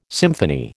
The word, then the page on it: symphony: American English